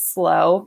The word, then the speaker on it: SLO: So this weekend we saw Dylan and Carly get married in Slow, which I thought it was S-L-O, but it's Slow because that's how you say it in California.